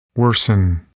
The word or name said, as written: worsen